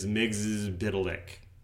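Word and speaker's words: Mxyzptlk: And is the man who killed Superman, so he knows his stuff, taught me that it's Miggs's Biddlick. – Yeah, I think they pronounce it in the first episode, in that Superman, the animated series episode, there's a beat where he can't pronounce it, and he's like, Miggs, yes, whatever, Biddlick, he spells it out for you phonetically. – Yep. And that's the more correct pronunciation.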